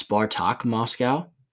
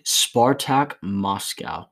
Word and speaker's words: Spartak: And it's Sparta Moscow, but since it's Russian, it's Spartak Moscow. Oh. It's like Sparta, but with a K at the end. Spartak Moscow.